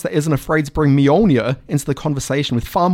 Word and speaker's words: Mjolnir: We've got Maria Lewis, who knows what a Mjolnir is and will tell me how to pronounce it properly. – Mjolnir. Obviously. If you'd seen Thor the Dark World, the cinematic classic, you would know it's pronounced Mjolnir.